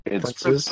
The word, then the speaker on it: Princes: It's Princes. Princes. – Because it's spelled wrong on the release. It's not Princess.